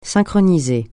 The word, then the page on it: synchroniser: francais